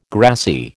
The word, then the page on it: grassy: American English